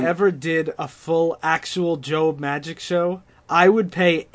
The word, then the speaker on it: Job: Nobody seems to realize that it's pronounced Job.